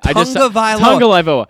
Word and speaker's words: Tagovailoa: Whenever I'm talking about, you know, Tagovailoa or however you say it, Tagovailoa. He spelled it out phonetically for you, and you still got it wrong.